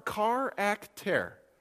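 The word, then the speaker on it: charakter (χαρακτήρ): The Greek word here looks like this. Yeah, okay. It's pronounced charakter. Say that with me. Charakter. Do you hear the English word in there?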